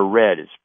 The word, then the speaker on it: redd: He photographed a pair of brown trout in the process of depositing eggs and milk in their red. It's spelled R-E-D-D.